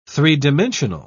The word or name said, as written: three-dimensional